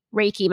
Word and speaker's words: Reiki: So one thing I really want to talk about is your work as a Reiki master.